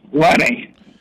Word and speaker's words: Lenny: Lenny. – Lenny. Do you spell it with a L-E-N-N-I or L-E-N-N-Y? – A Y.